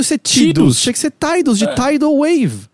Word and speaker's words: Tidus: A pronúncia correta é Tidus, mas eu me recuso, é Tidus.